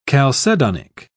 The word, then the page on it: chalcedonic: British English